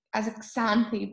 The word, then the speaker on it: Xanthi: The last name, it's pronounced within as a Xanthi, but it's okay.